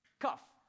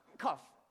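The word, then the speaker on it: cough (קוף): In order to get this number, you have to pronounce it in the back of your throat. So the k is like a... So it's... cough. Cough. Or cough, like that.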